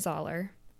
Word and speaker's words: Zollar: It's a mashup of my full name, Anna Zoller, rhymes with dollar,